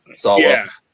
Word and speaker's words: Swalwell: Yeah, Swalwell.